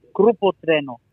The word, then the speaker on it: Grupo Trueno: You got Grupo Trueno, that's how you say it, right? – Grupo Trueno. – Trueno, okay.